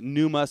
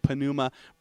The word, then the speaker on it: pneuma: And in the Bible, in the New Testament, you have the word, depending on your Greek pronunciation, some would pronounce it pneuma, some say pneuma.